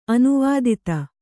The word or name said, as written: ಅನುವಾದಿತ